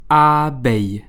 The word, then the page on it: Abeille: a.bɛj